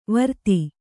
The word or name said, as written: ವಿರೋಧ